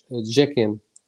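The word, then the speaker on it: Cekin: Okay, his name is Arman Jekin. It's with a C, but it's pronounced J.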